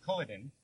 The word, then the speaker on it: Culloden: I think even specifically it mentions the Battle of Culloden. I'm probably pronouncing that wrong, so sounds right.